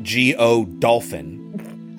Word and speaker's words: Godolphin: The book is a satire, and the whole quote goes like this Godolphin, who is a person, and it's literally spelled G O Dolphin. I looked up how do you pronounce this, and it's Godolphin apparently. It's not like Dauphin or something like Godolphin sat by the writing table of the deceased, shading his brow with the hand which the actress left disengaged.